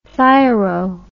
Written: thyro

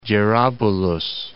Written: Jarabulus

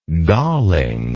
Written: gallingness

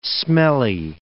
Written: smelly

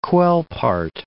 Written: Quelpart

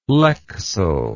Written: lexell

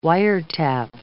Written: wiretap

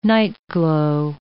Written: nightglow

